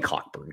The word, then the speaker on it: Cockburn: I agree with that, but my standard is kind of Kofi Cockburn, I guess is how it's pronounced. – You can say it. – It's Kofi Cockburn. Come on, let's be honest here. There's no such thing as a silent CK in any – there's just no such thing.